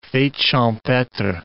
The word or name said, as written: fetes champetres